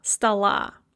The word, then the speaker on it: стола: They are also pronounced as A if they are one syllable before the stressed syllable. So for example, in the word стола, стол is written with an O, but when you add an ending, it becomes stressed, and so that O is then one syllable before the stress, and it is pronounced as an A, стола.